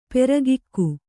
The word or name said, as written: ಪರಕೆಗೈ